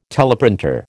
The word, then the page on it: teleprinter: American English